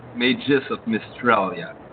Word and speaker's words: Mages of Mistralia: And well, first of all, how do you pronounce the game? Mages of Mistralia? – Mages of Mistralia. – Okay. – Like Images of Australia, but you have to change some letter into that.